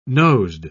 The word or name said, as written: nosed